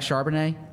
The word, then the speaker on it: Charbonnet: And then Zach Charbonnet, am I pronouncing it correctly? – Mm-hmm.